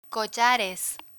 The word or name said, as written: collares